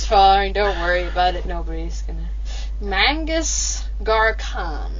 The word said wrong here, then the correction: Magus Garkhan: Am I saying that right? Mangus Garkhan. – Magus Garkhan. – Magus Garkhan. All right, Magus.